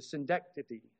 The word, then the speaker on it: synecdoche: We saw that last week, the synecdoche. I'm still not going to say it right publicly. Sorry. Synecdoche. You got it.